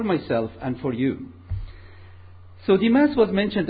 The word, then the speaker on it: Demas: But actually, we are going to study a person called Demas. I pronounce him that way. You probably pronounce differently. But Demas is a person who is mentioned in the Bible only 3 times.